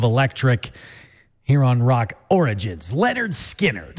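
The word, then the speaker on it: Lynyrd Skynyrd: And it was August 13th, 1973, 53 years ago today, where we finally heard pronounced Leonard Skinner.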